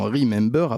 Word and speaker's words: Remembeur: On reçoit Ali Gessoum, fondateur de l'association Remember, à prononcer B-E-U-R en fin de mot, pour son ouvrage Attention, travail d'arabe et son travail de dénonciation des stéréotypes racistes et xénophobes.